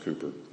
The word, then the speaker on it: Cowper: C-O-W-P-E-R, but it's pronounced Cooper.